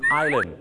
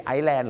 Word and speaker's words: island: Island ไม่ใช่ Iceland